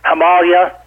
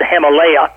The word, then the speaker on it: Himalaya: I've been to the twice I have trekked in the Himalaya, which some people mispronounce as the Himalaya.